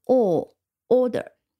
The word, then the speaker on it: order: 早上好，今天我们要讲一个常用词 order，o r d e r，嗯，读音很简单，稍微注意一下，第一个音节是重音，然后它是长音 o order。